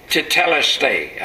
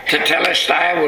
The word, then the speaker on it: tetelestai: And in this sixth last saying of Jesus, when he cried out, "It is finished," in the original language of the Bible, there's one Greek word, tetelestai. I guess that's the way you pronounce it. T-E-T-E-L-E-S-T-A-I.